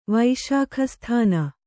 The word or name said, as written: ವೈಶಾಖಸ್ಥಾನ